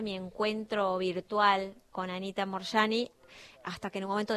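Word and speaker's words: Moorjani: Anita Morshiva se llama. – Morshani. Se escribe Morjani. – O sea, Morjani, con doble O, Morjani.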